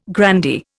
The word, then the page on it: grandee: Indian